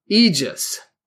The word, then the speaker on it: aegis: The next word is aegis. It's spelled A-E-G-I-S, aegis.